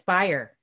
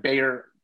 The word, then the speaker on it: Byer: So What you could say is it's pronounced like Breyer's ice cream, but it's Byer. – Right, because everybody wants to call it like Bayer aspirin.